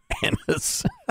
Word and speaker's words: anise: I like anise. It's one of my favorite things. – Are you sure that's the way it's pronounced? – Yes. – Okay. – Yes, I am.